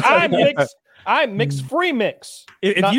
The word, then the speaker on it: Mx: It's pronounced like MIX. It's mix.